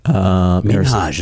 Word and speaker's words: Minhaj: Minhaj is how you say it. Yeah. You've been saying it wrong.